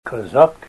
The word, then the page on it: kazak: Ninilchik Russian